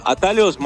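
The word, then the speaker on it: Atalhos: Claro, Atalos más que nada significa atajos en castellano, lo único es que se escribe con LHOS, pero significa atajos.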